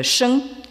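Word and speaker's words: sheng (生): qui se prononce en chinois moderne sheng.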